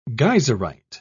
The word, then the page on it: geyserite: British English